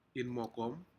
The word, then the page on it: inmokom: Aneityum